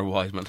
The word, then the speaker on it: Weidman: Wildman is the one who kind of... – Wildman or Weidman? – Weidman. There's an awful pronunciation error in my lifestyle.